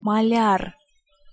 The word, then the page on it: maljar: Russian